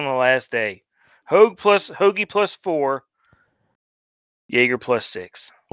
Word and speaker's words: Hoge: Uh, Tom Hoge. What a scumbag. – Hoagie.